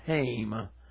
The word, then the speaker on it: hame: They pronounce the word home as hame, so I will use their pronunciation.